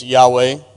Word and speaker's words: Yahweh: Uh, there's two very popular uh, pronunciations. One is Yahweh, you might have heard of that, and the other is Jehovah or Jehovah. We transliterate it with a J.